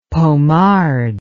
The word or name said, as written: pommard